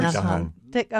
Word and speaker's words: Thich Nhat Hanh: And I may pronounce this improperly, Thich Nhat Hanh observes. – Thich Nhat Hanh.